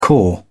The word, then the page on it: caw: British English